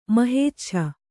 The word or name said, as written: ಮಹೇಚ್ಛ